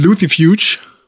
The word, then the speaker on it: Lucifuge: Und Lucifuge ist Latein. Und deswegen müsste man es Lucifuge aussprechen. Mein Lateinlehrer und der andere Lateinlehrer haben immer gesagt, C wird wie K ausgesprochen. – Ja, habe ich auch so gelernt. – Kein Mensch sagt Cäsar, sondern Cäsar. Also würde ich es gerne weiter in Englisch aussprechen.